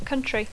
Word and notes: country: English

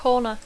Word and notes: corner: English

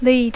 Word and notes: lead: English

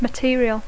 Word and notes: material: English

